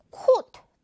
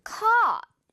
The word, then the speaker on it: caught: 자, A-U-G-H-T 이 어려운 부분 들어가 있는데 이 발음, 이 단어의 발음을 caught 또는 caught 이렇게 발음하는 친구들이 많은 것 같아요. 자, 그런데 이거 우리 맛있는 떡 발음이죠? 그래서 어떻게 발음되죠? 맞아요. caught 이렇게만 발음을 해줘야 돼요. 커, 웃도 아니고, 콧도 아니고요. 떡 조그맣지 않죠?